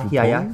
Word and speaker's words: Groupon: Groupon? – Ja, Groupon? – Ja.